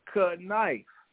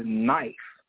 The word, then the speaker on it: knife: And he was really telling the truth, cut knife. But we pronounce, or the English word pronounces it as knife. But that's confusing as hell, because there's a K.